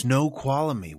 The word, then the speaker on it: Snoqualmie: Snoqualmie, I believe is how you say it. Snoqualmie. – Wow. It's S N O Q U A L M I E. Snoqualmie.